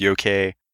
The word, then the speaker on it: Yoke: Then I did try extra hard to lead you astray with the yoke pronunciation, but as I believe Sherry pointed out, it is probably pronounced yoke, because other names for that language are also Yoki and Yauke, and the Tokpisin word for joke seems to be Giaman, not yoke.